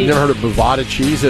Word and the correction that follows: burrata: No, burrata cheese. – It's Bovada. – No, it's burrata cheese. – Well, you don't have to roll your R's like a smart ass.